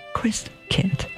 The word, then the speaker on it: Christkind: It all began in Advent when my seven sisters and brothers, were expected to prepare for the coming of Christkind, pronounced Christkind.